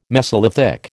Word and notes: mesolithic: American English

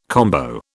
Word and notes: combo: British English

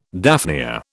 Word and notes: daphnia: British English

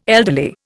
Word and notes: elderly: Indian

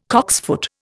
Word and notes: cocksfoot: Indian

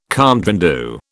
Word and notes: compte rendu: British English

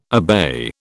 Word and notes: obey: British English

